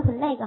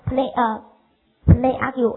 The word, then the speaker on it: plague: 你单词 plague，读成 plague 哈，听明白？读成不够，不要给我读成 play argue 哈，很恶心哈，听明白？这是 plague，OK 哈，这些单词能不能写出来？